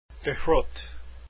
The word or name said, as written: de Groot